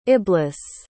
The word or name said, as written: iblis